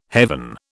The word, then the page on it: heaven: British English